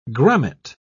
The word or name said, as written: grommet